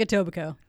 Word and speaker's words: Etobicoke: Just like Etobicoke. K is silent.